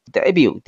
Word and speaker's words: debut: debut 首次呢登场，注意这个词，它的拼写是 debut，但是呢它后面这个 t 是不发音的。 make somebody or something debut 哈，表示呢首次亮相。